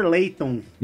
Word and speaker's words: Layton: É Professor Layton. Isso, a pronúncia foi errada.